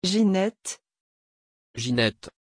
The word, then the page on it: Ginette: French